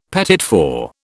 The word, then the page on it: petit four: British English